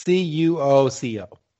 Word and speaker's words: Cuoco: Cloco. – It's C U O C O. Cuoco.